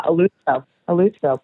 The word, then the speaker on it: Eleuthero: Am I saying this right? Eleuthero? Is that right? – Yeah, Eleuthero. Perfect.